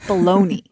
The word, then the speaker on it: bologna: Is it bologna? – This is America. It's bologna.